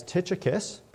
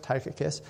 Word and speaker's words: Tychicus: Tychicus, or maybe some of you know him as Tychicus, but I have it on very good authority from a professor of Greek that his name is actually pronounced Tychicus, is a name we actually see five times in the New Testament.